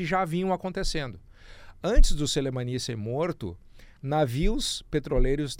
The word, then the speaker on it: Soleimani: Embora o assassinato do Soleimani, essa é a pronúncia correta, Soleimani, tenha sacudido, chacoalhado o Oriente Médio.